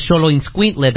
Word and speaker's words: Xoloitzcuintle: Although traditionally, like an X, you see an X, you're like, in Spanish, an X is still pronounced with a sh, like the Xoloitzcuintle.